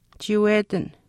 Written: chiiwetin

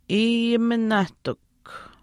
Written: iiyiminaahtikw

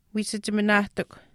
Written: wiisachiminaahtikw

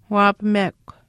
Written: waapamekw

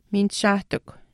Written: miinishaahtikw

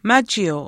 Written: maachiiu

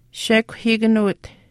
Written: shekuhiikanuwit

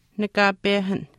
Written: nakaapehan

